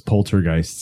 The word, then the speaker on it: poltergeist: Man, I remember when I was a kid, I used to pronounce it poltergeist, like poltergeist. You know what I mean? – Like, and then as I got older, I learned it was poltergeist.